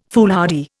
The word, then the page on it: foolhardy: Indian